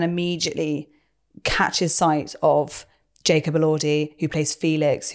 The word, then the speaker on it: Elordi: We've got long, gorgeous Jacob Elordi.